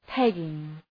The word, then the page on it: pegging: ‘pegıŋ